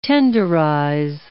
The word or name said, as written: tenderize